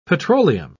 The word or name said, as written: petroleum